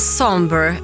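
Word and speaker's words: Sombr: Homewrecker med Somber. Sombre är Somber. Ska det uttalas tydligen.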